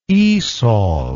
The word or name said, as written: ESOL